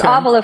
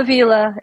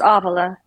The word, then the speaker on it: Ávila: It's Avila, folks, not Avila. It's Avila. If you want to know now, this is how you pronounce my name.